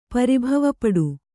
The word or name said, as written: ಪರಿಭವಪಡು